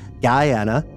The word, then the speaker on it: Guyana: Guyana is pronounced like Diana. Essequibo is pronounced with the Q-U-I like in quit.